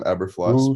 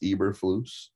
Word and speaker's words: Eberflus: And at the end of the day, they have said that this was Poles' final decision and to bring in Eberflus, is how you say it. I've been calling him Eberflus, but it's Eberflus.